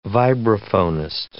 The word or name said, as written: vibraphonist